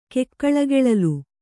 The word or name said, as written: ಕೆಕ್ಕಳಗೆಳಲ್